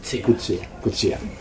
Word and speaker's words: Coetzee: Coetzee.